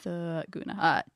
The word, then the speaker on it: Thurgoona: Thuguna. – Thuguna. Can you spell it? – Thuguna. T-H-U-R-G-O-O-N-A.